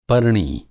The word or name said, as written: पर्णी